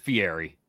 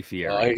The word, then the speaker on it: Fieri: However you pronounce his name. – Just Fieri. Come on. We're from Pittsburgh, man. Just say Fieri.